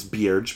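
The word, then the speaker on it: Byrge: Dwayne Bierge from Hollywood Reporter, and I always say his name is Bierge because I know him personally.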